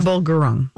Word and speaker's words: Prabal Gurung: Prabal Gurung? How do you say it? Prabal Gurung.